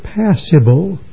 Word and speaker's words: passible: And therefore, a passible, that's spelled P-A-S-S-I-B-L-E, there are two words that are pronounced the same in English. Passable, A-B-L-E, and passible, I-B-L-E.